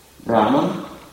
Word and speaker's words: Брахман: Браман, Брахман пишется, означает совершенный, опурнам, полное целое, самодостаточный.